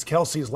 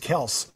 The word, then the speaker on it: Kelce: So, if you guys are so close, how come nobody knew that Travis Kelce's last name was Kels? – Man, he never corrected us. You know, it's crazy. Like, I'm like, man, like, we've really been saying his name wrong, like, for the past 6 years. Because I've been in the league 6 years, and I've been calling him Kelce, you know, for the past 6 years.